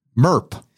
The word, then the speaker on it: MERP: MERP. – Yeah, so the MERP, it's a funny name, right? Rhymes with burp.